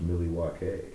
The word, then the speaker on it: Milwaukee: Oh, about Milwaukee is actually a Native American name. It's pronounced Milwaukee.